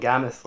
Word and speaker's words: Gammoth: I assumed you were just saying it properly, like, even though you're saying Glammoth and I'm just hearing Gammoth like every time.